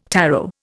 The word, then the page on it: tarot: Indian